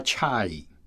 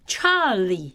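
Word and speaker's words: chai (чай): 而且俄文的茶的发音比英文来说更像我们现在讲的那个国语的发音。 – 你发一下俄文的茶。 – 他们的茶就叫 chai。 – chai。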